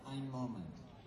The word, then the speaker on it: Ein Moment: Ein Moment.